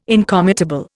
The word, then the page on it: incommutable: Indian